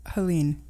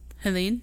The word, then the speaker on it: Helene: I've heard this, Helene. – Helene? – Yes. – I was like, could be fucking, could be Ellen, you know, it could be silent H, silent E at the end.